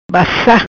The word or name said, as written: Bassa